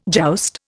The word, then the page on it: joust: Indian